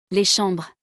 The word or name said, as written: Les chambres